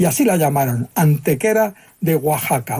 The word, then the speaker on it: Oaxaca: Entonces, esa palabra así escrita se pronunciaba Oaxaca, y hoy también, porque se ha mantenido, fíjate, la grafía española del siglo XVI.